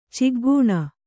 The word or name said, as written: ಚಿಗ್ಗೂಣ